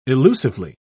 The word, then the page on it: illusively: British English